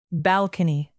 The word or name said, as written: balcony